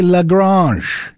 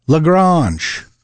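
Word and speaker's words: La Grange: You know, I got thinking about Home on the Range and LaGrange, and I wondered, am I even pronouncing that right? Well, I looked it up. It's actually LaGrange. It's French. It literally means the barn in French, LaGrange. It's a town in Texas, LaGrange. Got its name from French origins. Although today, Texans pronounce it simply LaGrange.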